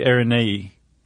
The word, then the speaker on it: Araneae: No, I think she said like it's pronounced like it looks. – Oh, okay. – So it would be Araneae.